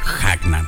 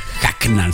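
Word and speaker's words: Hucknall: Seguimos avanzando con esta banda que exploró más allá del talento de propio Mick Hagnan. ¿Eh? Hagnan se pronuncia ahí. Mick Hagnan, como Mick Jagger, Mick Hagnan.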